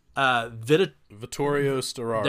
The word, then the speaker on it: Vittorio Storaro: Then you also got the cinematographer, and I'm gonna butcher this name, Vittorio Storaro.